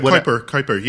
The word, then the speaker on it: Kuiper: Yeah, Kuiper, yeah.